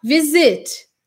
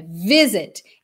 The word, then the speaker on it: visit: A gente não vai dobrar essa consoante em inglês quando a última sílaba do verbo ou do adjetivo não for a tônica ou não for a mais forte. Então, por exemplo, visit. Percebe que a gente não fala visit, é visit.